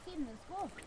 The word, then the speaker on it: Finnesko: How do you say Finnesko? – Huh? – Finnesko, the deerhide boot. – Oh, Finnesko. – Finnesko. – Finnesko. – Finnesko. – Yeah. – Thank you. – Good. – I've been pronouncing it wrong for 6 years. Finnesko. – Finnesko. – Finnesko. – Yeah. – It is Sami? – Finnesko, it's like Finnish shoes.